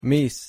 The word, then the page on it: mis: Spanish